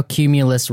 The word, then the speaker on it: Cumulus: And they've I like how Cumulus rhymes with accumulate.